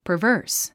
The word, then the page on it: perverse: pərvə́ːrs; パバース